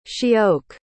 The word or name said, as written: she-oak